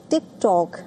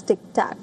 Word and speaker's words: TikTok: While boasting of his popularity on social media, he also repeatedly mispronounced the name of TikTok as Tic Tac.